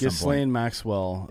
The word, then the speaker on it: Ghislaine Maxwell: Ghislaine Maxwell.